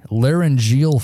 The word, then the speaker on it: laryngeal: laryngeal fracture? – Nailed it.